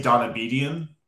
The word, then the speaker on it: Donabedian: Yeah, so I don't even say it right, to be honest. So I say Donabedian because that's just like the phonetic easy way to say it. But if you're being truly an Armenian, you say Donabedian.